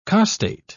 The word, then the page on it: costate: British English